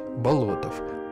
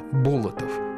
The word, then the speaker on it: Болотов: Имя – Андрей Тимофеевич Болотов, хотя чаще его фамилию произносят неправильно – Болотов.